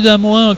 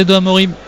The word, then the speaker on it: De Amorim: On dit bien De Amorin, je le dis, hein, depuis le début de la saison, tout le monde dit mais pourquoi tu dis De Amorin, que c'est De Amorim? Ça s'écrit De Amorim. En portugais, ça se dit De Amorim. Mais lui souhaite qu'on dise De Amorin, Mathias De Amorin. Donc je dis Mathias De Amorin comme il le souhaite lui-même.